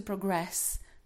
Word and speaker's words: progress: Right, a progress, pokrok, but to progress. Stress is on the second syllable, okay?